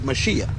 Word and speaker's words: Mashiach: It's the word Mashiach. We pronounce it Messiah in English.